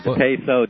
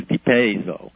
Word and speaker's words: DePesso: There's a DePesso, DePesso. I think the traditional Italian pronunciation is DePesso.